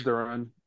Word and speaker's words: Duren: Or is it Duren or Duran? I don't know how to say his name. – I think it's Duren.